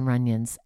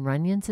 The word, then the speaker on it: Runyons: Runyons, spelled like onion, but with R-U.